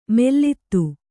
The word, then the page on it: ಮೆಲ್ಲಿದು: kannada